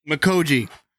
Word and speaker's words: Mikolji: Please, could you pronounce it for me? – Mikoji. – Yep, I always want to say Miko. Mikoji.